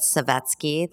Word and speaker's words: Savetsky: My name on Instagram is my name, Elizabeth Savetsky. That's S-A-V-E-T-S-K-Y. And my website is Accessories Expert, spelled E-X. C-E-S-S-O-R-I-E-S, expert.